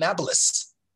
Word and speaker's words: Nablus: Arabs don't have a P in their pronunciation, so they call it Nablus.